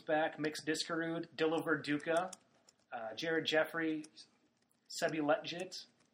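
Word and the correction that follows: Lletget: Letgit is actually the pronunciation. – Yep. – Letgit.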